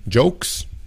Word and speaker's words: joke: Muchas veces los hispanos dicen como yoke, yoke, como yolk, como si fuera la yema del huevo. Yolk. No, no, no, es joke, joke.